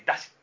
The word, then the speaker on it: 山車: その山車と書いてだしって読むんですけど、はい、だしっていう言葉がふわっと変わってだんじりという言葉になったっていうウィキには書いてあったんですけど、まあ、大阪の岸和田とかが有名ですよね。